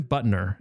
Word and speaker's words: Buttner: Tim Buttner.